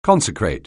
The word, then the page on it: consecrate: anglais